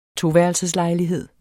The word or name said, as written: toværelseslejlighed